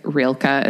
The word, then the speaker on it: Rilke: And also I'm not 100% sure if Rilke is the pronunciation, but y'all know who I'm talking about. It it is.